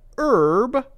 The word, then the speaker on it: herb: But because Americans say herb instead of herb, we don't say herb, we say herb, we say an herb.